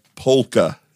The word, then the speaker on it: polka: I believe you have to pronounce the L, Matt. – Oh, sorry. – It's polka.